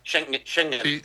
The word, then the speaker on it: Schengen: Schengen.